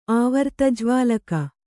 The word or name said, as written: ಆವರ್ತಜ್ವಾಲಕ